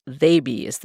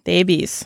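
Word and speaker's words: Theybies: And finally, Thabies. That's how you pronounce it, right, Noreen? – Thabies? Yes, Thabies. – Rhymes with babies.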